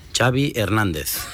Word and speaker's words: Xavi Hernández: Xavi Hernández.